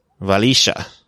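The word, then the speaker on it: Wlaschiha: Valisha. – Say it again. – Valisha.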